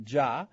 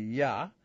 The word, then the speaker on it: Yah: Except there's no J in Hebrew, but there is in English, so they pronounce it Jah, but in Hebrew it would be Yah.